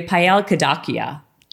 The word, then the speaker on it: Payal Kadakia: We have Payal Kadakia.